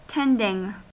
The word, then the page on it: tending: American English